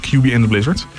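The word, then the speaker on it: Cuby + Blizzards: Geschreven als QB plus Blizzards altijd. – Echt? – Zeker. Oh. Ja, maar dat wordt uitgesproken als QB and the Blizzards.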